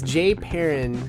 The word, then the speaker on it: JPearin: It's Jay Perrin, J-P-E-A-R-I-N, so people stop mispronouncing the name.